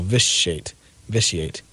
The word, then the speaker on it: vitiate: So, and that word apparently is with an SH sound in the middle, vitiate.